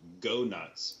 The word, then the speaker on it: Goughnuts: The one brand I love and will plug endlessly is called Go Nuts, but it's spelled GOUGHNUTS.